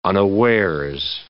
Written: unawares

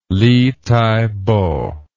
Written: Li Taipo

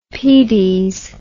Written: pedes

